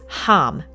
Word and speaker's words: ham: So one of the seed syllables for the throat is ham or H-A-M. Pronounce it the same as the vowel for hot rather than ham, like the food, and draw out the vowel sound in your throat.